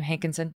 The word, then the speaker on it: Hankinson: Is that how you pronounce his last name? Hankinson? – Yes.